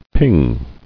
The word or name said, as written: Pinging